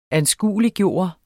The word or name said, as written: ANSKUELIGGJORT